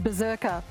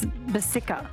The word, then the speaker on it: Berserker: And apparently the suburb isn't said Berserker, which is kind of disappointing. I should have asked. It's Bersicka. Bersicka, I think, is how you say it.